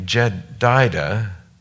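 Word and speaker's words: Jedidah: His mother's name was Jedidiah. Did I say that right? Jedida, the daughter of Adiah of Bozkath.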